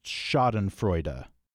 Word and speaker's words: Schadenfreude: I think it is pronounced Schadenfreude.